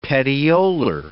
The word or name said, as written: petiolar